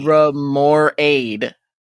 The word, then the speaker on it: Remoraid: Raid. See, R-A-I-D at the end? What is that? That's Raid. – Remoraid. See? Remoraid. Remoraid.